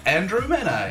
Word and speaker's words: Andrew Mena: You can also tweet at me at Andrew Mena, A-N-D-R-E-W-M-E-N-A.